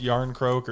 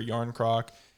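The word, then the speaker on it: Jarnkrok: J-A-R-N. – You've already lost Marshawn. You've already lost him. – K-R-O-K. – Skittles. – And it's pronounced Yarncroke or Yarncroc.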